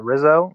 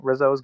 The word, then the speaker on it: Rizzo: Yeah, Rizzo. Rizzo is good.